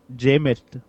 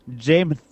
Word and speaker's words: Jameis: The name is not pronounced Jameis. – What is it? – Jameis. – Jameis? – It's supposed... Oh, I did it. It's supposed to be the name James with a lisp.